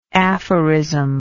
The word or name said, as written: aphorismatic